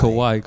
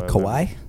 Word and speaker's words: Kawhi: Did I say that right? Kawhi or is it Kawhi? – It's Kawhi. Come on, man. – Kawhi.